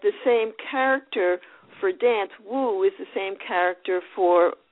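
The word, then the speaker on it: Wu (武): So the Chinese character for martial arts is Wu, and also the Chinese character for dance is Wu, W-U. So it's the same pronunciation.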